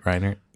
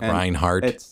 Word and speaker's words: Reinert: Did I say it right, Reinert? – That is correct, okay. Yes. A lot of people say it wrong. – Like Reinhardt? – I get that all the time.